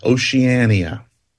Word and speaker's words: Oceania: Five syllables clocking in at an astonishingly scant seven letters is the word Oceania.